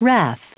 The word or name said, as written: wrath